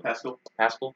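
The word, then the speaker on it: Paschall: And then Eric Paschal is number 2. – Pascal. – I think it's pronounced Pascal. I believe.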